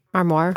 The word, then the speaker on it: armoire: but my parents had this armoire. Am I saying that right? – Armoire. – Armoire? – Like a soft R, I think.